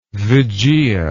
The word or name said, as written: vigia